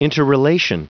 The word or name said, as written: interrelation